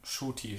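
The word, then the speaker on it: Ncuti: Aber kannst du dir gleich mal merken, Shuti spricht man den Vornamen aus.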